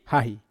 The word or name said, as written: hahi